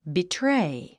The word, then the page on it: betray: bitréi; ビトレイ